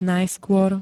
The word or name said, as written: najskôr